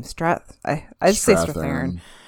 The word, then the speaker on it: Strathairn: They're still at the tryouts, and Ira Lowenstein, David Strathairn's, how do you say his name? Strathairn. – Yeah.